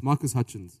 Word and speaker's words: Marcus Hutchins: All right, so the guy who basically came up with the way to stop WannaCry, Marcus Hatchkins. Is that how you pronounce it? – Yeah, it's Marcus Hutchins, yeah.